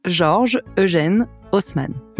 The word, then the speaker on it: Georges Eugène Haussmann: It was led by a man named Georges Eugène Haussmann, whose name I will pronounce the English way. In French, it's more like Georges Eugène Haussmann.